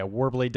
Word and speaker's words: Worbli: and it's called Warbly. I hope I said that right. – Yeah, Warbly, W-O-R-B-L-I.